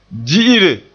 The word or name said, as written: di'ire